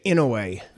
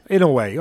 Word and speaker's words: Inoue: Is it Inoue or Inoue? – I would say Inoue. – Inoue, okay. Inoue, it might be Inoue in that sort of way.